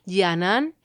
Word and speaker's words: Canan: Y la cantaba Serdar Yanan, este cantante realmente excepcional, cuyo nombre se escribe Serdar, como suena, y Yanan es con C, Canan, y es una grabación que nos ha hecho llegar él mismo.